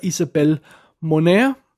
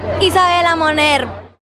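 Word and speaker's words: Isabela Moner: Hun hedder Isabel Monaire. Nej, sådan udtaler man det vist ikke. Lad os lige prøve at høre, hvordan man rigtig udtaler det. Isabella Moner. Sådan, så skulle der være styr på det.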